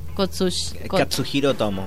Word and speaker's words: Katsuhiro Otomo: Obviamente décadas después, como menciona Cero, apareció lo que fue Memories en 1995 por Katsushiro Otomo. ¿Lo dije bien? – Katsuhiro Otomo. – Katsuhiro, ahí está.